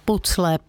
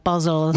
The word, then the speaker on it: puzzle: Tak jestli rádi skládáte pucle, puzzle, záleží, jak to kdo vyslovuje, tak my se tomu budeme věnovat, respektive Martin Písařík už za chvíli.